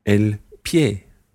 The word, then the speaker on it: pie: The word for foot is el pie.